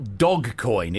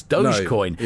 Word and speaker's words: Dogecoin: Yes, it's not Dogcoin. It's Dogecoin.